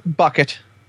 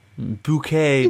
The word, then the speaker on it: Bucket: Hyacinth Bucket. – Bouquet, darling.